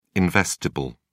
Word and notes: investible: British English

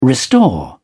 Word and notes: restore: British English

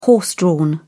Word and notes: horse-drawn: British English

